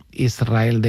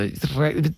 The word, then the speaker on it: Israel: Y de diminutivo, de chiquitito, Israel, que ella quería... Porque Israel creo que le sonaba muy grande, ¿no? Israel, ¿no? – Y la S con la R es difícil de pronunciar.